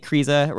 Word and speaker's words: Kryza: Andy Kryza rhymes with Cheesa, of course, also on Thrillist, writes a lot, I guess.